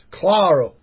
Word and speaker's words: Claro: And by the way, people, it's Claro. Paul Claro. It's a fucking my excuse for my fucking error. – Yeah, that helps. If you pronounce it with the accent, it helps. Claro.